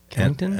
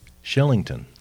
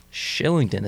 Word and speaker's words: Kylington: You'll never guess this in a million years. Shillington. – Shillington. It starts with a K. – Starts with a K, but it's Shillington. He's Swedish. – Oh. – And it's pronounced Shillington.